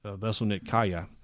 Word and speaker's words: Veselnitskaya: I'm going to butcher the name. Veselnitskaya. – Nailed it.